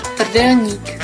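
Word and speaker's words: Trdelník: Jens zegt al in de Nostalgie-app, je moet zeker eens de Tardelnik proeven. Eigenlijk moet je het zo uitspreken. – Tardelnik.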